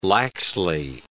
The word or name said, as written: laxly